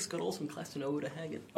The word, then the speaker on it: Uta Hagen: You also study under, I'm going to butcher the name, Uta Hagen.